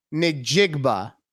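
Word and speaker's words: Njigba: Jason, or no, I'm sorry, Jaxon Smith Najigba. N I or N J I G B A. I'm not being racist. That's how you say it.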